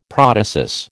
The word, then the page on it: protasis: American English